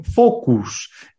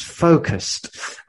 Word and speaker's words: focused: I mean, every day people say to me, focus. It's not focus, it's focused.